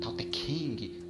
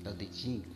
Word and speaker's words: Tao Te Ching: Tanto que o livro que todo mundo fala, ah não, Tao Te Ching, Tao Te King, não sei o que, um monte de pronúncia tudo errada, é que é Tao Te Ching, Tao Te Ching, se falaria de uma pronúncia mais correta, embora o mandarim seja bem básico mesmo.